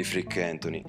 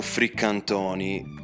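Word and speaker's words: Freak Antoni: Tra l'altro, insomma, diciamo che c'è stato un anniversario un po' spiacevole perché il 12 febbraio era l'anniversario della morte di Frick, di Frick Anthony, o Frick Antoni, come insomma, diciamo, erano le due pronunce su cui ci si orientava, insomma, che richiamano un po' il fricchettone e quant'altro.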